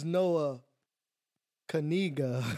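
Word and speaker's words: Noah Knigga: The name is Noah Kaniga.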